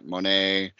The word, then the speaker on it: Monat: Monet. Well, it's spelled M-O-N-A-T. – Yeah, it's Monet. Yeah. – Really? – I thought Monet was M-O-N-E-T.